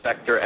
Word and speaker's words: Spector: My name's spelled with an I, Brian, and then Spector, S-P-E-C-T-O-R.